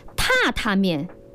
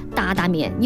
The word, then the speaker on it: 榻榻面: 哎，其实这个达达面哈，我们普通话正确的发音应该就是叫做榻榻面，边榻的那个榻，但是我们四川人啊，就一般就叫它达达面，因为嘛，就搭的那个就是这个案板上哒哒哒的响，哎，所以说也这样的发音更加的地道有意思哈。